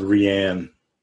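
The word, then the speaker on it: Rian: I thought it was Ryan Johnson. – No. – I thought so. – I don't think so. – It's pronounced Rianne.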